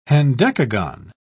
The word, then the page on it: hendecagon: British English